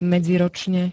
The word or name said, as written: medziročne